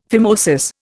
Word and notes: phimosis: Indian

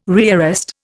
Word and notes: rearrest: Indian

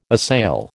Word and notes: assail: American English

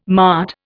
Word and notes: mart: Indian

